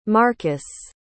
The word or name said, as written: markus